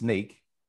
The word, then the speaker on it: Snyk: It is pronounced Snyk and it stands for, so now you know, think of Snyk, Snyk. Some people in Europe call it Snyk, but yeah.